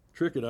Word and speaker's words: Triquet: Trickett Island, and that's spelled T-R-I-Q-U-E-T, is 250 miles above Vancouver, Washington, but about 1,000 miles south of the most southeast corner of the Alaskan border.